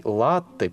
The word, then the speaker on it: латте: Пенистый латте пишется с двумя буквами «Т», а ударение находится на первом слоге.